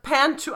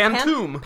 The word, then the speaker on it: Pantoum: That was somebody who tweeted at us and said, I really hope the next summary is in the Pantoum. I didn't even know how to pronounce it. – Pantoum.